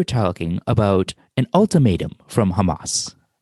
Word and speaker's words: Hamas: And that's when things get bad to worse, because then there's a threat from Hamas. – It's pronounced Hamas. – Oh, get out of here, really? I've only seen it on paper, I never heard it pronounced before.